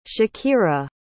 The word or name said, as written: shakira